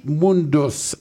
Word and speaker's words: Mundus: Euh, Mundus. Est-ce que je le prononce comme il faut? – En plein ça.